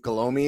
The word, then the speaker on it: Kilome: Uh, I believe Kilome is is how you say it.